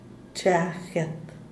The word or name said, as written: cha guet